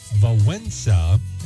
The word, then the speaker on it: Wałęsa: Lech Walesa, the Polish solidarity labor activist whose name was pronounced Lech Wałęsa, actually. He served, even though there's not an N in there. How do you get a pronunciation of when there's not even an N in the name?